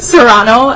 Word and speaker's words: Serrano: First name, Myrna, M-I-R-N-A. First, second last name, Serrano, S-E-R-R-A-N-O, and second last name, Barahona, B-A-R-A-H-O-N-A.